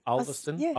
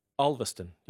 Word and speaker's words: Ulverston: Ulverston.